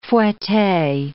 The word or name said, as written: fouette